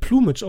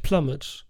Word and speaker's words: Plumage: Das wird ja, ich glaube es jetzt, es wird Plumage ausgesprochen, ne? nicht Plumage. Hört sich bescheuert an, aber The Bird with the Crystal Plumage.